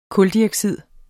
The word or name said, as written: kuldioxid